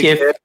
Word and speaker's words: GIF: Someone said it's a hard G. All right, yeah. – animated GIF. All right, whatever you like, either one.